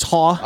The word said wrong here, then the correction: Hauk: No, it's Hauk. It's Hauk. You said it right there.